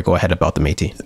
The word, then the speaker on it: Métis: Métis.